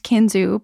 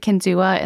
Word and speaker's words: Kinzua: It's pronounced Kinzu by locals, but Kinzua in the Seneca language.